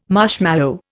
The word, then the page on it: marshmallow: Indian